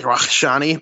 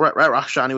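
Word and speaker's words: Rakhshani: He's Iranian, so it's more like Rakshani, but still Rat Rakshani would be more of the American pronunciation.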